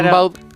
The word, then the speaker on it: Rimbaud: Claro, se pronuncia Gambó, pero es claro. – Rimbaud.